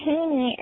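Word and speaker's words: Hoenig: My name is Ashley Haney and that's H-O-E-N-I-G. I realize that's probably not the right pronunciation, but that's how my family's done it for years, so I'm going to keep it.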